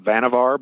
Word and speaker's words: Vannevar: And as to who pushed this decision forward, the person making the recommendation to the president was an engineer named Vannevar Bush, spelled Vannevar, but it's pronounced Vannevar.